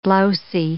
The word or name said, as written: blousy